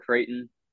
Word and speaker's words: Creighton: and uh, one thing um, about Creighton that I think our guys should do to get fired up is Creighton rhymes with Dayton.